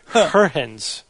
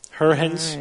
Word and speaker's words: Huygens: It's Christian Huygens, but I actually his name, I listened to the pronunciation of his name online, and if the internet is correct, it's Huygens. Huygens.